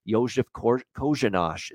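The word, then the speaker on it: Kořenář: Josef Kojanosh is how you pronounce the name, even though it's spelled like coroner. It's actually Josef Kojanosh for Arizona in net.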